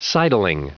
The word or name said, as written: sidling